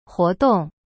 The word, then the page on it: 活动: huo-dong